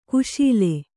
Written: ಖುಶಾಲ್

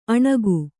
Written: ಅಣುಗಿ

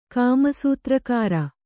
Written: ಕಾಮಸೂತ್ರಕಾರ

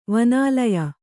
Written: ವನಾಲಯ

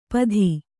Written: ಪಧಿ

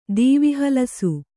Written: ದೀವಿಹಲಸು

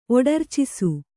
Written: ಒಡರ್ಚಿಸು

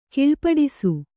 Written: ಕೀಳ್ಪಡಿಸು

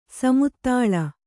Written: ಸಮತಳ

